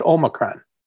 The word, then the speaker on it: Omicron: It's pronounced Omicron.